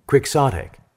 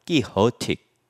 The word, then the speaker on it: quixotic: 이때 돈은 안 쓰고요. 키호티라고 읽어요. 그러다 보니까 키호틱. 돈키호테에서 돈자를 빼면 키호티가 되고 키호틱 그러면 돈키호테식의 돈키호테는 공상 속에서 비현실적인 사람의 대표죠.